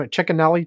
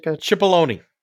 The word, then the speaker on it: Cipollone: The big question is, how do you say his name? Chickenelli? Chicken? – Cipollone.